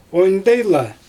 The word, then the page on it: ondyjla: Xyzyl